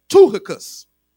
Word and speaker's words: Tychicus: But the name that really catches me off guard is the name we pronounce in Tychicus, but his name is actually pronounced Tuchicus. His name means faithful, not faithful, but faithful. F-A-T-E-F-U-L.